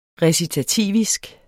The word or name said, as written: recitativisk